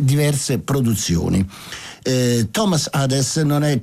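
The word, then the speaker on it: Adès: Lo so che l'accento è sulla E, però una volta intervistato fu lui stesso a dire: no, il mio cognome è Hades.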